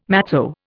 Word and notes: matzo: Indian

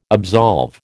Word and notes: absolve: American English

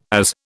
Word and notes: AS: British English

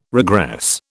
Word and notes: regress: British English

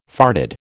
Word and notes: farded: American English

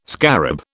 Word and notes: scarab: British English

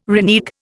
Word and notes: renege: Indian

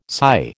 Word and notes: SY: American English

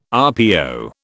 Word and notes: RPO: British English

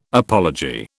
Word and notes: apology: British English

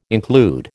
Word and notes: include: American English

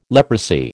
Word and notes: leprosy: American English

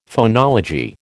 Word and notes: phonology: American English

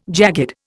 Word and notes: jagged: Indian

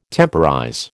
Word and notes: temporize: American English

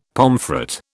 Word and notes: pomfret: British English

